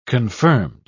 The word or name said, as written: confirmed